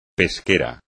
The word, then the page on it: pesquero: Spanish